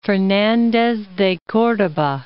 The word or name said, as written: Fernandez de Cordoba